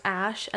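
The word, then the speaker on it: Æ: So it's X, the letter X, the AE is pronounced Ash, and then it's A12. So X-Ash A12. How stupid.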